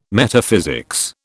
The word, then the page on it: Metaphysics: British English